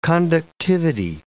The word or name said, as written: conductivity